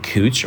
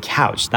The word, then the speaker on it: Couch: Like, do you know how to pronounce, is it cooch or is it couch? That is such a dead giveaway. Like, I don't know. I think it's on Couch Street.